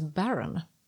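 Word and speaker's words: bodhrán: Med lite fiddle och mandolin och eh, sån här handtrumma som stavas bodran men tydligen uttalas baron.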